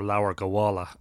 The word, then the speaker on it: Leabhar Gabála: But the invasion myths belong to a book called Leabhar Gabála, or Leabhar Gabála, as it would be pronounced in modern Irish, and that is the Book of the Takings of Ireland, or the Book of Invasions, as it's commonly known.